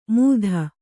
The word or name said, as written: ಮೂಧ